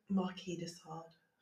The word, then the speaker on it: Marquis de Sade: Is that how you say it? – Marquis de Sade.